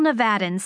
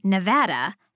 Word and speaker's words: Nevada: I know that when real Nevadans say Nevada, the VAD rhymes with mad. Only newscasters broadcasting from Rockefeller Center say Nevada.